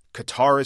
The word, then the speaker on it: Qatar: But officially, they want me to say it's sort of like guitar. We called the embassy. They said Qatar is fine.